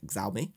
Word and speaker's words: Xiaomi: Exactly like that. That's how they should actually pronounce it. Xiaomi.